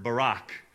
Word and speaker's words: barak: You're going to learn a new Hebrew word, because the Hebrew word for blessing is the word barak, all right? So think Barack Obama, and you can spell it B-A-R-A-K, and it has two meanings.